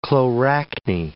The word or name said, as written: chloracne